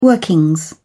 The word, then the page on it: workings: British English